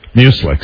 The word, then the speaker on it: muesli: Muesli. – Yes. Yes. Mm-hmm. Which rhymes with useless. Mm-hmm. Have some useless muesli. – I always thought it rhymed with mucus. – Yeah, it sounds like mucus.